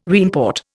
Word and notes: reimport: Indian

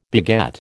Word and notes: beget: American English

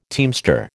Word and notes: teamster: American English

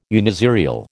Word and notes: uniserial: American English

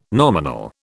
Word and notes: nominal: British English

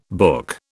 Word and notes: book: British English

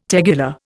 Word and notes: tegular: Indian

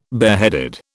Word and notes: bareheaded: British English

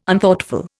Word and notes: Unthoughtful: Indian

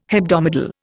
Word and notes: hebdomadal: Indian

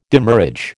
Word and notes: demurrage: American English

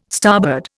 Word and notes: starboard: Indian

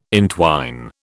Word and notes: entwine: British English

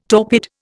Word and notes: torpid: Indian

